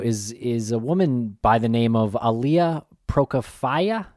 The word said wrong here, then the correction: Prokofieva: Is that how you pronounce that, Jen? – I mean, I'm not too good at my Russian pronunciation, but I think it's Prokofieva.